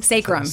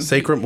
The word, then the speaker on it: sacrum: I got, how do you say that? Sacrum? – Sacrum. Sacrum.